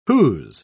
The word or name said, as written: whose